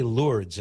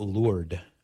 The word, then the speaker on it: Lourdes: Just like I say Lourdes and not Lourdes, because we don't say Paris.